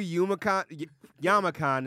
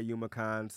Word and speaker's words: Youmacon: Doc, I had to do Yamacon this year, by the way. I found out it's Yamacon, not Yumacon, so I've been saying that wrong for the past like 7 years.